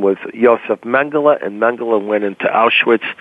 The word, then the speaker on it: Mengele: Mengele.